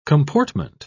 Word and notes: comportment: British English

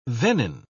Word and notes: venin: British English

